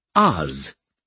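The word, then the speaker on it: AOZ: You may call me Oz. That's A-O-Z. And all capitals, by the way. – Gotcha. The A is silent, I mocked. He'd pronounced it Oz.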